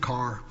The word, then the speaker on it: Ker: Even though my name is spelled K-E-R, we do pronounce it Carr.